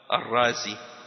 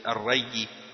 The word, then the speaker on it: Ar-Razi: ils ont rajouté un Zay à la fin, pour que ce soit plus facile pour eux de prononcer. Donc, ils ont dit, pour la personne qui vient de là-bas, Ar-Razi. Parce que la ville, c'est Ar-Ray. Sinon, ils auraient dit Ar-Rayi. Mais là, ils disent Ar-Razi.